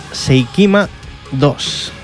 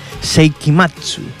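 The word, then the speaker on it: Seikima II: Por ejemplo, la que vamos a escuchar ahora, una banda llamada Seikima 2. Seikima 2, que en realidad se pronuncia Seikimatsu. Seikimatsu, esa es la manera de pronunciarlo en japonés.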